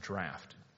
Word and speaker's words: draught: It's actually pronounced draft, but anyway.